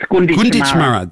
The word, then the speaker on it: Gunditjmara: Now, first of all, I said that wrong, didn't I? Is it Gunditjmara? Is that how I say it? – It's Gunditjmara.